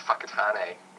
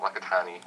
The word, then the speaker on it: Whakatane: Yeah, it's pronounced Whakatane, not Whakatane.